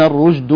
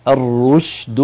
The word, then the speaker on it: الرشد: لو قل الهواء الذي يخرج مع الشين ستكون قريبة من الجيم، لكن الجيم فيها رخاوة، ستكون كهكذا: قد تبين الرجد من الغي. الرجد سمعتم الرج، لكن الصحيح الرشد من الغي. في بعض الحروف إذا تقاربت يعني إذا تجاورت يتأثر النطق فيها. إذن الرشد وليست الرجد.